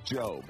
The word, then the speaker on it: GOB: And, or that's Job. It's spelled Gob.